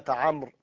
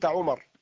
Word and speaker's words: عمرو: يقال إن الواو كتبت ليفرق بين الكلمتين، وإلا لو أنك كتبت عمرو، كتبت كلمة عمرو بدون واو ربما نطقتها بعمر أو قلت عمر، وهذا خطأ. مثلا إذا قرأت عمر بن الخطاب، عمرو بن العاص، فلو كتبت عمرو بدون واو لقلت عمر ابن العاص، وهذا خطأ.